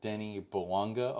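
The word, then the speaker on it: Bouanga: I know Buonga had multiple chances, and Denis Buonga, because I've been hearing it as Denis Buonga a lot. – Yeah, me too. – In like the stadium and whatnot. So I guess that's his correct pronunciation.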